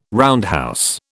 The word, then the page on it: roundhouse: British English